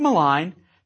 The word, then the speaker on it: malign: Now, in a later lecture, we'll devote considerably more time to words like malign that refer to all sorts of verbal attacks, such as philippic and calumniate. Now, why is there a silent G in malign?